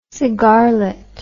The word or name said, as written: cigarlet